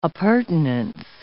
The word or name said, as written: appurtenance